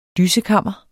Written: dyssekammer

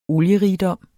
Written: olierigdom

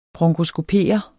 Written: bronkoskopere

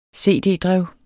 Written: cd-drev

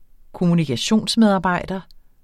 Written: kommunikationsmedarbejder

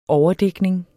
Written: overdækning

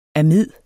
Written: amid

